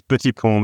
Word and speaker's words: Petitpont: How'd we do there on the pronunciation of your last name? I feel like I butchered it, so I'll let you correct the record here. – Yeah, it's Petitpont, but actually, it's quite tough to say by non-French speaker in this time.